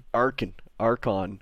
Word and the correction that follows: Akron: Well, how do you say it? – Akron, man. – Akron. – You don't know the kid from Akron, LeBron James?